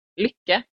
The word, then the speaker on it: lykke: If you're mad, lykke. That's yeah. how you say it, lykke.